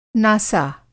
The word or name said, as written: नासा